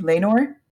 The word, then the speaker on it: Laenor: Laenor? – Yeah.